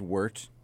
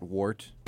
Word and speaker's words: wort: So you're basically making wort, pronounced wort, not wort.